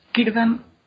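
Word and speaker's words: Círdan: Bueno, pues la, la C siempre se pronuncia como K. O sea, siempre es Kirdan, Kelleborn, etc.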